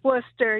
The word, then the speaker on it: Worcester: It's Worcester, yes.